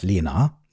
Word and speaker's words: Lena: Und Lina ist ihr Name. Also auf Deutsch geschrieben und ausgesprochen wäre es Lena.